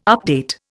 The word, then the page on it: update: Indian